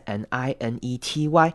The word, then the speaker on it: ninety: In 96, 这边要特别注意的是， 90 本身是 ninety，那这边 t 的声音我们会念成的，弱化变成像是 d 的声音，不是 ninety，而是 ninety six。